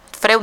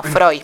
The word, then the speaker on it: Freud: Freud no, Freud.